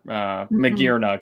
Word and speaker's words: Magearna: Or Magearna, excuse me.